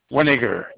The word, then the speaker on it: Weninger: Winiger, W-E-N-I-N-G-E-R.